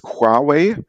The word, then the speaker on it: Huawei: Also die korrekte Aussprache, wenn ich es jetzt versuche, weil ich jetzt auch kein perfektes Chinesisch spreche, ist Huawei. Aber wenn man Huawei sagt, ist das auch okay. Und ich glaube, die meisten verstehen dann, wovon wir reden.